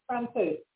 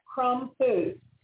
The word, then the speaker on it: Krompowitz: Did I pronounce that correctly? – Krompowitz. – Say it again. – Krompowitz. Two syllables.